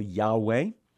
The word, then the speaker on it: Yahweh: Remember that God gave to Moses the name that is pronounced, as far as we can tell, Yahweh, which in Hebrew means He who is.